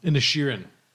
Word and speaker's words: Inisherin: I'm going to say that wrong. – Inisherin.